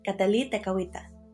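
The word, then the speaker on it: Kateri Tekakwitha: Hi, today I'm going to be talking about my patroness, Catalí Tecahuita, the Lily of the Mohawks. That's the Mohawk pronunciation of her name.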